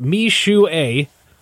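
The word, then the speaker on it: Mixue: It's pronounced Mi Shu A. I probably butchered it, but that's the pronunciation guide on their website.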